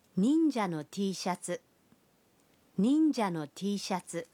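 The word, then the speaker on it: টি শার্ট (Tシャツ): টি শার্ট শব্দটির জাপানি উচ্চারণ হল টি শার্ট,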